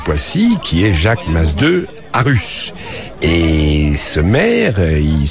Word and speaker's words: Masdeu: Oui, monsieur Jacques Masdeu Arus. – Oui, voilà, vous prononcez en catalan là, Masdeu.